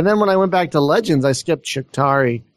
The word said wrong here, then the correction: Shakturi: How do you say that? – Shakturi, yeah. – Shakturi. Excuse me. I always mess that up.